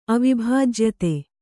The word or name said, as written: ಅವಿಭಾಜ್ಯತೆ